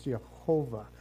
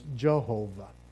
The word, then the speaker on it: Yehovah: The word Lord in the Hebrew is Yahweh or Yehovah. I'm sorry. It's Yehovah, or the way we pronounce it in English, Jehovah.